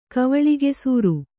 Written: ಕವಳಿಗೆಸೂರು

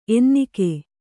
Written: ಎನ್ನಿಕೆ

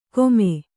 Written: ಕೊಮೆ

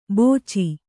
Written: ಬೋಚಿ

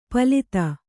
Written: ಪಾಲಿತ

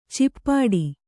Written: ಚಿಪ್ಪಾಟಿ

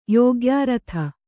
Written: ಯೋಗ್ಯಾರಥ

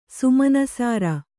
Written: ಸುಮನಸಾರ

